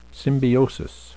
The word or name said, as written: symbiosis